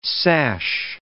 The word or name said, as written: sash